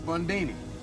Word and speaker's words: Bundini: Bundini. Rhymes with Houdini, yeah, except that don't rhyme.